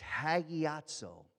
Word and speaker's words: hagiazo: It's hagiazo. Come on, say it with me. Hagiazo.